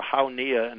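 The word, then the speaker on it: Hau Nghia: The mission basically was to be the forward air controller for Hau Nia, and that's spelled H-A-U, second word, N-G-H-I-A, Hau Nia province,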